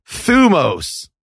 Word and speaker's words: thumos: Thumos, not thymos. Oh. Actually, ancient Greece doesn't have vowels. So it's like, it's just scholar bullshit. But if you see that word thymos, it's thumos.